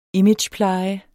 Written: imagepleje

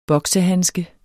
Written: boksehandske

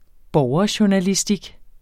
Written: borgerjournalistik

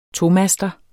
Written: TOMASTER

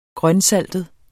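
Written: GRØNSALTET

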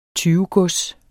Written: tyvegods

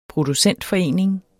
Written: producentforening

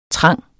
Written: trang

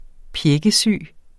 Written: pjækkesyg

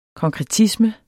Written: konkretisme

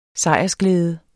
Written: sejrsglæde

